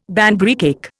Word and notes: Banbury cake: Indian

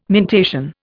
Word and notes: mentation: Indian

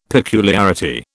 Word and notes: peculiarity: British English

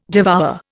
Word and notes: devour: Indian

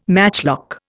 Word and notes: matchlock: Indian

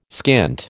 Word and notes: skint: American English

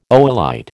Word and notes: oolite: American English

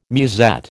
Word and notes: musette: American English

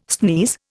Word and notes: sneeze: Indian

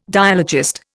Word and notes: dialogist: Indian

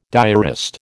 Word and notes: diarist: American English

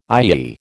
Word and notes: IEE: American English

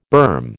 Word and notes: berm: American English

